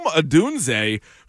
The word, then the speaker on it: Odunze: Okay, I'm going to go Rome Odunze, who was the House of Fire. – Can you spell that for me? – O-D-U-N-Z-E.